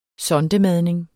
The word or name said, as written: sondemadning